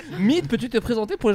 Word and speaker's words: Myd: donc ça s'écrit MYD mais ça se prononce Mid,